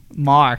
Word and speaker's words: Maher: It's not Mayer. It's Marr. – Stephen Marr. – And the only reason we know that was I didn't tell Tom, but I Googled a YouTube video on how to pronounce that. The last name is spelled Maher. And I'm like, is it Marr or Mayer?